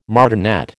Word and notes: martinet: American English

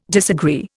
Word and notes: disagree: Indian

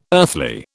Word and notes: earthly: British English

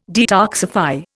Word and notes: detoxify: Indian

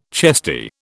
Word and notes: chesty: British English

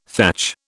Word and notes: thatch: British English

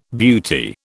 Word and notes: beauty: British English

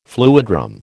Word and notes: fluidram: American English

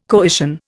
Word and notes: coition: Indian

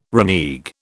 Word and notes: renege: British English

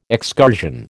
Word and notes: excursion: American English

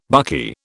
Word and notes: buckeye: British English

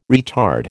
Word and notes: retard: American English

